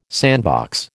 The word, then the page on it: sandbox: American English